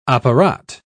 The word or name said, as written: apparat